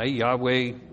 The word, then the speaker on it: Yahweh: Yahweh is the best way we know how to pronounce it.